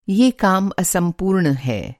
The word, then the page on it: असम्पूर्ण: incomplete